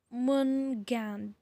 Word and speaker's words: Jormungand: So one more of Loki's children was this thing called um Jor Jormungand. Jormungand. I don't know how to say it. It's like see, it's it's like J O R M U N G A N D.